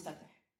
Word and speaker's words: Fatah: It is spelled in all the Western literature as F-A-T-A-H, Fatah, but actually in our Palestinian dialect, we call it Fatah.